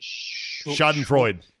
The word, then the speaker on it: Schadenfreude: Schadenfreude. – Schadenfreuden or whatever.